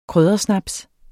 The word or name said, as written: kryddersnaps